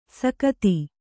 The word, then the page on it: ಸಕುತಿ: sakuti